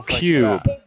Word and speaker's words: Kube: De spiller på det, der hedder Cube, tror jeg, man udtaler K-U-B-E på Cube.